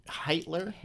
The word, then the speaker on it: Heitler: So I found an article from Susan Hitler. She's a Heitler, not Hitler. – H-I-T-T-L-E-R? – H-E-I-T-L-E-R.